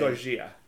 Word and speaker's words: Gojira: Gojira. That's how Jose Mangan pronounces it on Liquid Metal, so.